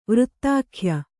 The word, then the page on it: ವಾರ್ಧಕ್ಯ: kannada